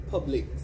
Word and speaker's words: Publics: Or Publix. It's Publix, but not Publix. Like, it's spelled P-U-B-L-I-C-S.